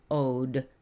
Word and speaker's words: Owed: Our next review is Ode, and that's spelled O-W-E-D.